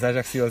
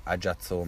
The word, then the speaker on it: Ajaccio: Tehát, jó, ugye korzi, hogyha korzikai kiejtés szerint, vagy francia kiejtés szerint akarjuk, mindegy. Csak azt mondom, hogy akkor következetesen maradjunk egyiknél vagy a másiknál, de ne Ajazzo, meg nem tudom, tehát hogy, hogy ne ilyenek legyenek,